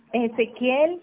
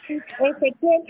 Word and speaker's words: Ezekiel: Ezequiel Isaac Becerra Landaeta. – ¿Con doble A o con doble C? Como... – Mira, Ezequiel se escribe con K, en inglés. E-Z-E-K-I-E-L. – Ya, disculpe, que E-Z-E... – E-Z-E-K.